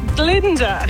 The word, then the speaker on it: Glinda: It rhymes with Tinder. – Tinder. – Yes. Yes. It's Glinda.